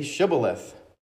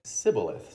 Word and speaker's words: Shibboleth: And when he said, "No," they said to him, "Then say Shibboleth." And he said, "Sibboleth." So the SH sound, I guess, couldn't say. He said Sibboleth, for he could not pronounce it right.